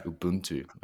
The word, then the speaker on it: Ubuntu: but Ubuntu. – Ubuntu.